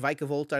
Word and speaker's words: Vikavolt: It was Gibby Archer Tang winning with the Vikavolt deck, and it is pronounced Vikavolt, I learned this weekend, so I'm going to try to work on saying it correctly.